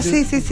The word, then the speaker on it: Naturgy: Naturgy Energy. – Ah, Naturgy.